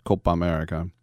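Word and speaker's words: Copa América: Remember, don't pronounce the A twice. Copa America.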